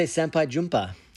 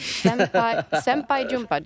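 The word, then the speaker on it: sampai jumpa: Yeah, how do you, how do you say You would say senpai jumpa, which is like see you again.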